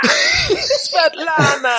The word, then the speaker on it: Svetlana: and this is like when Khrushchev is telling how do you say her name, the sister's name, Svetlana. – Svetlana.